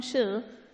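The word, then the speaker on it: shi (鼠): Wir sehen es auf Chinesisch, es wird ausgesprochen shi.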